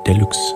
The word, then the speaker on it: Deluxe: Spricht man Deluxe dann auf Englisch so aus? Deluxe. – Ich sage ja.